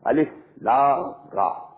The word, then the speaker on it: Alif Lam Ra: Not pronounced as Alif Lam Mim Alam, no. Alif Lam Mim. Alif Lam Ra Alar, no. Alif Lam Ra.